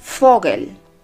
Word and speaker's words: Vogel: la pronunciamos como que si fuese una letra S, una letra F, perdón. Ejemplo, Vogel,